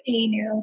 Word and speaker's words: Anu: My name, fortunately, is two syllables. It's Anu.